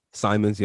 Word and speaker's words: Simons: Anthony Anthony Simmons, is that you say his name? Simons, yep. Simons, yeah.